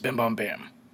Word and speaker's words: Bim Bom Bam: The band's Kanikinik is fronted by a Hmong woman named Sulur, whose stage name is Bim Bom Bam. To pronounce her name correctly, you have to say it very fast, almost missing some of the consonants.